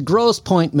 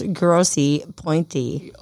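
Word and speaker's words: Grosse Pointe: It's spelled gross with an E at the end and point with an E at the end. So it's grossy, pointy.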